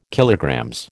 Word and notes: KG: American English

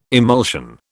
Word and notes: emulsion: British English

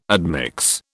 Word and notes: admix: British English

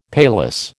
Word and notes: paillasse: American English

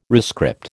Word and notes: rescript: American English

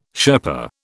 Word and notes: Sherpa: British English

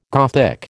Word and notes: Gothic: American English

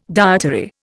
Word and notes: dietary: Indian

